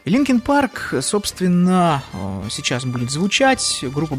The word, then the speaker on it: Linkin Park: Дело в том, что на сленге местном Линкольн произносится как Линкин. Ну и, в общем, так и получилась эта группа Linkin Park.